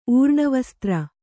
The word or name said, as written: ಊರ್ಣವಸ್ತ್ರ